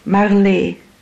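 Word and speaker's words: Marlé: Yes, Marley. – And how do you spell that? – M-A-R-L-E, accent aigu. It's got an accent on it. It's French.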